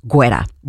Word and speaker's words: Guetta: So, David Guetta, or Guetta, depending on how you like to pronounce it. He pronounces it Guetta.